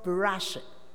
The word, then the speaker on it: Bereshit: The book of Genesis, in the Hebrew, is pronounced as, the name of the book in Hebrew is called Bereshit, which means in the beginning.